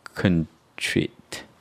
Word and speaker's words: contrite: 기독교에서 유죄를 인정하는 걸 contrite. Contrite라고 읽어야 되는데 convince.